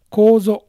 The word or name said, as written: Kozo